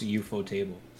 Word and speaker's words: Ufotable: Actually, I googled it while we were talking about it. It's actually pronounced UFO table. Spikey was right.